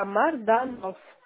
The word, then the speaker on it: Марданов: Марданов, первая буква М, Михаил. – Марданов, правильно? – Да, Марданов. Как произносится, так и пишется.